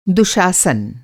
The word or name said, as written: दुशासन